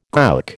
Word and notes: gowk: American English

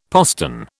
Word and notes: postern: British English

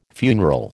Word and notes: funeral: American English

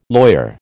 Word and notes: lawyer: American English

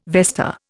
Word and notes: vesta: Indian

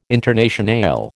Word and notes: Internationale: American English

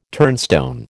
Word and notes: turnstone: American English

